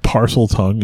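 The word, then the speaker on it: Parseltongue: Parsel tongue, if you're going to say it right.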